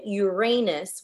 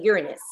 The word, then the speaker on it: Uranus: Some pronounce it Uranus, while others say Uranus. Can you confirm which is the correct pronunciation? – Both and neither. So basically, people use them interchangeably. So you can't go wrong with using either one of them.